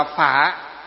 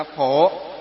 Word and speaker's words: Phổ: Chữ Phổ với chữ Phả đó là cái phiên âm thôi. Miền Bắc thì chúng ta đọc là Phả. Miền Nam thì đọc là gì? Là Phổ.